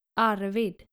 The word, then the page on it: Arvid: skandinavische